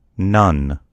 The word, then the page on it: nun: American English